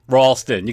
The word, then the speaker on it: Ralston: I definitely went too far with the pronunciation last show. Ralston, you got to have a little bit of a W in there.